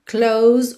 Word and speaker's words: close: So close, close, o som termina no S, esse S tem um som de Z, mas o E não é pronunciado, ok?